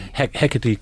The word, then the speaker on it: Hecate: It was a sex novel, or a novel that had a lot about sex in it, called The Memoirs of Hecate County. Is that how I pronounce it? – Hecate County.